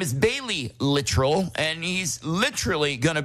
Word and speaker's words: Littrell: Brian Littrell from the Backstreet Boys, and it's pronounced Littrell, not Littrell. I know that because the record label, like, got on me for it. They're like, don't say Littrell, it's Littrell.